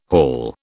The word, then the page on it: haul: British English